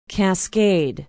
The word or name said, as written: cascade